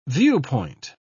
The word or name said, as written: viewpoint